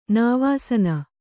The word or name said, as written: ನಿವಸನ